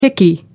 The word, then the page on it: hickey: American English